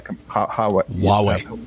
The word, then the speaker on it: Huawei: and particularly with this Hawaii or Highway, I don't know how you pronounce that. – Huawei. – Thank you.